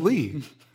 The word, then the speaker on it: Leigh: Lee. – Oh, of course. – Spelled. – L-E-I-G-H.